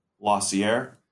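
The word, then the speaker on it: Losier: You had Michael Lossier. Is that how you pronounce his name? – Yeah, I think it's Lozier.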